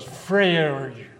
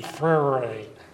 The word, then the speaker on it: Freire: Here goes Freire. And everybody has trouble with pronouncing it. Starts with an F and ends with an R and an E. Freire.